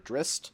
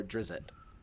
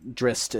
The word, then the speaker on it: Drizzt: The appropriate pronunciation is either Drist or Drizzt, which is two that have been listed. – Okay. – But Drist is like the appropriate pronunciation.